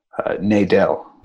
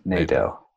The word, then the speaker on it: Nadel: Nadel. So kind of a long A, I guess. Nadel.